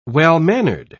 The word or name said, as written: well-mannered